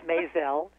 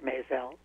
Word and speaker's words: Mizell: It's Mizell, Eric Mizell. Like Mizell. – May and Gazelle.